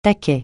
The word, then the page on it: taquet: francais